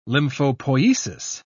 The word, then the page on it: lymphopoiesis: British English